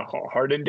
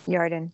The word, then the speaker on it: Yarden: How do you say the Yarden Day Fiestas? – Yarden. – Oh, like a Y.